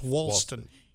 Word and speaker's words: Walston: Also, Tanya Wicker Hunt and Julie Wicker Crisco from Wicker Squared at Coldwell Banker, Howard Perry, and Ralston. And that's not right. It's Walston.